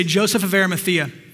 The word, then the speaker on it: Joseph of Arimathea: Say Joseph of Arimathea. Great pronunciation. I saw you, Jeff.